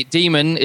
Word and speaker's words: Daemon: And by the way, Daemon is pronounced the same as demon in the English vocabulary.